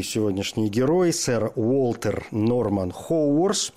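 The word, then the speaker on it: Haworth: Впрочем, в э, русской транскрипции иногда произносят Гевард или Ховард. Так что, если где-то встретите имя Гевард, то знаете, что Хоуворс и Геверт – это одно и то же лицо.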